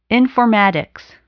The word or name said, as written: informatics